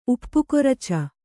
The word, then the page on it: ಉಪ್ಪುಕೊರಚ: kannada